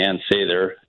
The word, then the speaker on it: Ann Sather: I mean, Ann Sather, as I'm told you're supposed to pronounce it, is like the last holdout at Belmont and Sheffield.